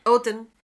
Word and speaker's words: Odin: Am I saying that right? – Odin, in Valhalla, or to Freya in Folkvanger, and are usually accompanied by ravens.